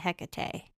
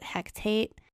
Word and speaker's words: Hecate: There are multiple ways to spell it. But we are going to say Hecate. I think in Practical Magic, they pronounce it Hectate. It always made my eye twitch a little bit.